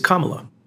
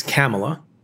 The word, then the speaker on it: Kamala: Most people, and the acceptable way of pronouncing her name, it seems, in America, is Kamala.